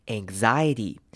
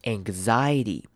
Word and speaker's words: anxiety: Anxiety, anxiety 在念完 G 的声音后面要接一个 Z，所以呢 X 这边念成 GZ, GZ，所以标准念法是 anxiety, anxiety, anxiety。